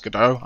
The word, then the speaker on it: Godot: Godot. I just use the, you know, like waiting for Godot pronunciation as per like the classical work or whatever.